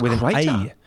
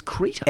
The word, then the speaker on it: Creta: So I thought it was Creta. I thought it was Creta. – Is that how you pronounce it? – Well, I spoke to Hyundai's PR boss and he laughed at me and said, oh, that's a good one. So clearly that's not how you pronounce it. Creta, as in... – It's Creta.